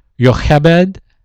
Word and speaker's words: Jochebed: Amram, A-M-R-A-M, that's his dad. Jochebed, which is spelled J-O-C-H-E-B-E-D, that's his mom.